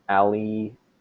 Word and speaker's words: Aliyevich: My name is Michael Aliyevich. And people listening at home can't see, but I just broke down my name phonetically. It just ignore the spelling. Unless, even if you're Polish, you probably can't pronounce it.